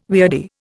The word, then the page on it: weirdie: Indian